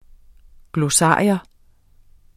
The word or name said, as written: GLOSSARIET